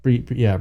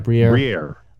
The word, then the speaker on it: Briere: They weren't screaming, oh, you know, fire Danny Brodeur. I almost said Brodeur. How the fuck do you pronounce Briere. – Briere.